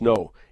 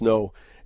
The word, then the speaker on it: Ngo: If you haven't seen it, you can go to YouTube and type in Andy NGO, pronounced no, NGO, pronounced no, and just have a look at it.